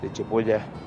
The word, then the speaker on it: Chepo Yá: muy bueno con respecto a la historia de Chepo Yá, también me dijo que es Chepo Yá, no Chepo Yá. Y sí, tiene acento en la última.